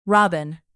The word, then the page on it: robin: American English